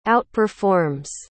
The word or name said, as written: outperforms